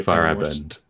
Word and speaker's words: Feyerabend: I can always struggle to pronounce his name. Is it Feyerabend? You just said it. – Feyerabend would be the Feyerabend.